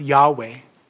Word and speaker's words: Yahweh: We believe it's pronounced Yahweh. So if you read that, then Yahweh rained on Sodom and Gomorrah sulfur and fire from Yahweh out of heaven.